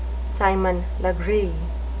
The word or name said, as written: Simon Legree